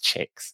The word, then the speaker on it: TJIGX: which I discovered is pronounced chicks, a web channel focused on promoting diversity.